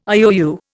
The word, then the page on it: IOU: Indian